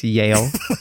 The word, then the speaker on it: Jael: You're correct. It's Yale. yeah, yeah, yeah, Yale, Yale, Yale, Yale, and that's J A E L.